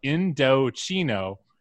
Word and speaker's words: Indochino: I also want to say that this ad read at the top says, note, Indochino is pronounced Indochino, and I don't know how else you'd pronounce it.